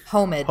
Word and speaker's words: homage: anyway, am I just pronouncing um, the H in homage? okay, all right. – Uh, you were also putting the emphasis in the wrong place.